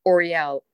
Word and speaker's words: Orielle: It's Osprey, O-S-P-R-E-Y. My middle name that I use as my name also fully is Oriel, O-R-I-E-L-L-E, and then my last name, Lake.